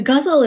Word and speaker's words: ghazal: And one is a ghazal.